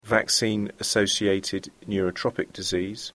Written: vaccine assorted neurotropic disease

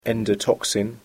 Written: endotoxin

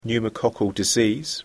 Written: pneumoccal disease

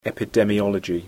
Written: epidemiology

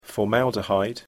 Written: formaldehyde

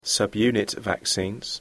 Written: subunit vaccines

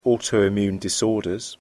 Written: autoimmune disorders

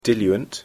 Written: diluent

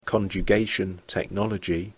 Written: conjugated technology

